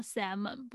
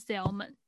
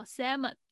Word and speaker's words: salmon: 好，就是我们两个礼拜前讲那个真鲑鱼之乱的时候呢，鲑鱼的英文发音那个 L 是不发音的，它叫 salmon，不是 salmon，叫 salmon。